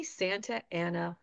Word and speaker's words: Santa Ana: If you are a Spanish speaker, you are going to want to say Santa Ana.